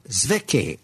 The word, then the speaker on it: Zwicky: And now we go to a Swiss astronomer, Zwicky, I believe is the correct way to say it.